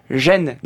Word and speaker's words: Ren: de on ne dit pas reine mais gêne,